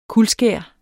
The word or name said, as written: kuldskær